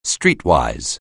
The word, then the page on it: streetwise: anglais